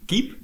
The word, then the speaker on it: KAEP: Eh, staan is keep bestaat het nog? nu Keep. – Keep? Ja, K-A-E-P.